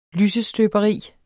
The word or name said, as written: lysestøberi